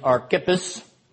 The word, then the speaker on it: Archippus: All right, now that brings us to uh, the second page, and the pronunciation is archippus, emphasis on the second syllable.